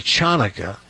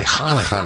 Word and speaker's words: Hanukkah: Well, that would be Chanukah. It's actually Hanukkah.